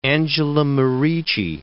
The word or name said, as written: Angela Merici